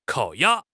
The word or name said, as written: kao3 ya1